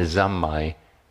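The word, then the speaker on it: zammai (三昧): And the zammai is the Japanese way of pronouncing the word samadhi, which means a continuing contact, you know?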